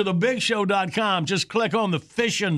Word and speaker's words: Fission: You get through and take C, you'll get a Fishing Cycles prize pack. Sounds like fishing, but it's spelled F-I-S-S-I-O-N. – So it's the nuclear word.